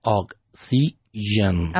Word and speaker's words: oxygen: Oxygen. – Okay, so three syllables, right? – Okay. – Oxygen. Tell me again. – Oxygen. – Listen to how I separate it, okay?